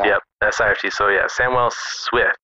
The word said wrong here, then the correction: Sift: Sift. Darn it, I keep saying it wrong.